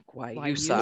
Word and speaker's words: guayusa: So the word there is guayusa. – Guayusa. I'm going to practice. – Guayusa. It does have a really cute little USA in the middle of it.